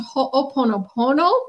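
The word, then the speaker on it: Ho'oponopono (Hoʻoponopono): And I am impressed you actually pronounced Ho'oponopono correctly.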